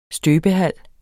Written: støbehal

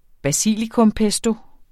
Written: basilikumpesto

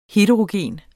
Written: HETEROGENT

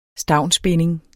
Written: STAVNSBINDING

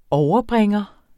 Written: overbringer

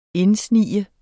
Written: indsnige

